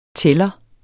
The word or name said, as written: tæller